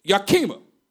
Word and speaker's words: Yakima: I heard one guy, was over there in um, Seattle, he said Yakima. I said, I went up all oh, probably, yeah, man, we're going to Yakima. He said, Yakima? It's like, man, I've been saying it wrong all this time.